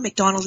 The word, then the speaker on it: McDonald: McDonald is spelled M-C-D-O-N-A-L-D.